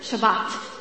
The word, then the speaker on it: Shabbat: Sabbath is just, Sabbath is an English mispronunciation of a Hebrew word, Shabbat. Okay? And Shabbat, if the best I can define it quickly, in a quick, one sentence definition, if we can have it on the screen.